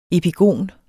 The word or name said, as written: epigon